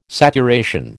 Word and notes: saturation: American English

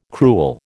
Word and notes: crewel: American English

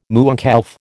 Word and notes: mooncalf: American English

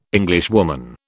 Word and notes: Englishwoman: British English

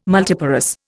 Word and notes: multiparous: Indian

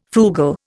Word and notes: frugal: Indian

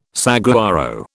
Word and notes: saguaro: British English

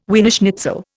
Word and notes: Wiener schnitzel: Indian

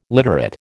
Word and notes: literate: American English

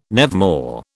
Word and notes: nevermore: British English

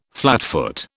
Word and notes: flatfoot: British English

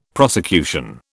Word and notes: prosecution: British English

